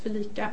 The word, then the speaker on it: FeliCa: heter Felicia, eller uttalas också Felica,